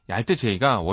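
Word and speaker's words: alt-J: 알트 제이가 원래는 제가 이렇게 발음은 알트 제이로 하지만 어, 수학 기호로 되어 있어요 이름이.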